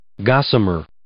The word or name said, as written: gossamer